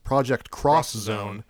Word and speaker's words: Project X Zone: I guess it's actually supposed to be pronounced Project Cross Zone.